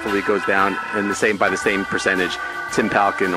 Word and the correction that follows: Parkin: Well, and what did I said his name wrong? – Parkin.